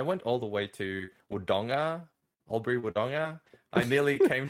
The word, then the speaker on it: Wodonga: Wodonga. With a hard G.